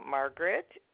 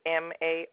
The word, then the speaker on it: Margaret: Margaret. You want me to spell it? – Yes, please. – It's Margaret.